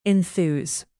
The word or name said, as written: enthuse